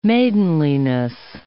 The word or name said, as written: maidenliness